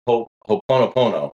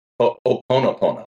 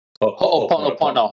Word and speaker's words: ho'oponopono: I used to say this a different way. I just learned that I was saying it wrong because it's Hawaiian. I call it ho'oponopono. – Yep. – It's actually pronounced ho'oponopono.